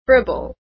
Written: fribble

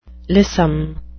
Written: lissom

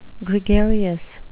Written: gregarious

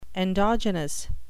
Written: endogenous

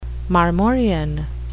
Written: marmorean

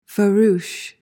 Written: farouche

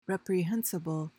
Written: reprehensible

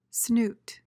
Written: snoot

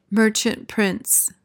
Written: merchant prince

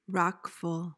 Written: wrackful

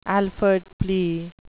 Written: Alford plea

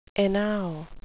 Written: enow

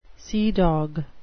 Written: seadog

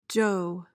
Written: joe

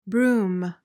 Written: brume

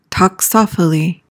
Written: toxophily

